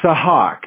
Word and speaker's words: Sahak: It's Sahak, S-A-H-A-K. But a lot of people just call me Hawk, H-A-W-K. I mean, whatever's better for you. Maybe a little easier to pronounce for sure.